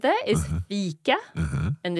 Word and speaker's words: fika: So you have a long E sound in fika. So E. And in ficka, I.